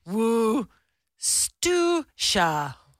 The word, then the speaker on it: Worcestershire: How is Worcestershire actually pronounced? It's pronounced Worcestershire.